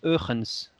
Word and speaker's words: Huygens: Ouais, H-U-Y-G-E-N-S, Huygens. Alors en hollandais, j'ai vérifié, ça se prononce Eugens, rien à voir.